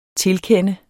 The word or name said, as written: tilkende